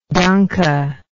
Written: danke

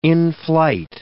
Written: in-flight

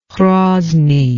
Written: Hrozny

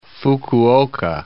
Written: Fukuoka

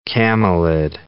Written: camelid

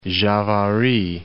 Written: Javari

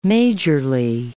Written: majorly

